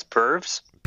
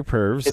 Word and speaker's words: Purves: Peter Pervs? – Yeah, why not? P-U-R-V-E-S, Pervs. – Peter Pervs. – It sounds wrong, but I think that's how it's pronounced.